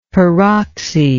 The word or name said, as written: peroxy